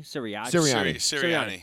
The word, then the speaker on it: Sirianni: Sirianni.